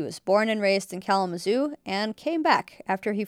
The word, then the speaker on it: Kalamazoo: They'd say Kalamazoo, Kalamazoo. Yeah, that's the way they pronounce it, like they would wanted to say it right. That's the way of pronouncing the A and the L and Mazo. They didn't say zoo, they said zo.